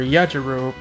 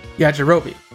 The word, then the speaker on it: Yajirobe: did the voice for Yajiro, I believe I said that right? – Yajirobe.